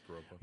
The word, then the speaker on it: Viroqua: It's Viroqua, isn't it? I always say it wrong. – Yeah, Viroqua.